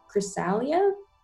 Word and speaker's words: Chrysalia: I know that you're debuting your first album, Chrysalia.